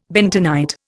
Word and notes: bentonite: Indian

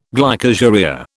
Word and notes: glycosuria: British English

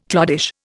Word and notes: cloddish: Indian

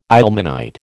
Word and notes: ilmenite: American English

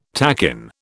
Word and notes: takin: British English

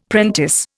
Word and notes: prentice: Indian

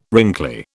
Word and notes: wrinkly: British English

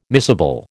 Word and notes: miscible: American English

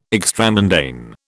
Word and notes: extramundane: British English

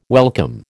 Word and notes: welcome: American English

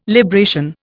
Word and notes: libration: Indian

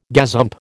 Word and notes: gazump: American English

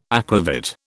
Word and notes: aquavit: British English

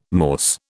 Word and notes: Morse: British English

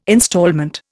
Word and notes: instalment: Indian

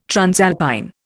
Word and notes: transalpine: Indian

